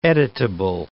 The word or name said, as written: editable